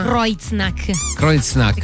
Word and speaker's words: Kreuznach: Kreuznach. Perché EU si legge OI.